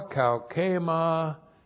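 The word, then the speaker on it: kaukema (καύχημα): And then we have ta kaukema hemon.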